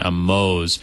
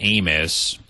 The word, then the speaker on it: Amoz: You know, and that name, you know, I was kind of using the Hebrew pronunciation, Amoz. But that name sounds like Amos, you know, but it's not.